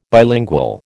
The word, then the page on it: bilingual: American English